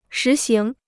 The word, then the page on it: 实行: shí xíng